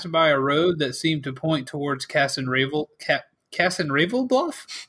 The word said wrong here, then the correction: Ravenloft: Castle. – No, that's one of my favorite. You said it wrong. – Castle Ravenloft, which is pop quiz.